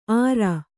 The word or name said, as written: ಆರ